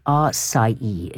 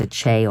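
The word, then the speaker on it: açaí: The top of the list is A-C-A-I. That's the most commonly mangled word. R-C-I-E is apparently how you say it, not a Che or a Kai or a Aki or anything else like that. R-C-I-E.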